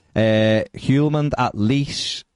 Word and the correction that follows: Lecce: at Lecce, rather.